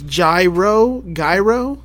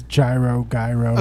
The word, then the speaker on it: gyro: Just so I pronounce it right. It's gyro, gyro. – Yeah, it's gyro, gyro.